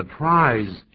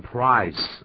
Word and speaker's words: prize: The prize 要变念成 price 啊，这是小地方的发音要记住。 – 对，这个 z 是念作 z 而不是 s 的音。